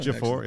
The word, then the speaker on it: Ejiofor: He would tell Ejiofor, is that how you say it? – Yes. – Yeah. – Well done. – Ejiofor, yeah. Benedict Wong and Xochitl Gomez.